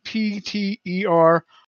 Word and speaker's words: pterosaur: Pterosaur. It's a sore. Some kind of a sore. A petersaur. Obnoxious. What's that? – What did you spell? Pterosaur? – Yeah, pterosaur. Yes. I guess the P is silent.